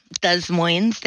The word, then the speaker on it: Des Moines: Des Moines, they do pronounce the S.